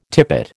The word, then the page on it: tippet: American English